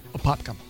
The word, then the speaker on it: Apopka: That headline obviously coming out of Apopka, is that how you say it? – Apopka.